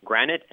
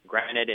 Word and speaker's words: Granat: Yeah, it's Edward is spelled E-D-W-A-R-D, Edward, and then my last name is spelled G-R-A-N-A-T. So it's kind of like the rock, but it's spelled differently, like the stone, granite, but it's spelled differently. So it's Dr. Edward Granite,